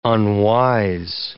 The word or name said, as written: unwise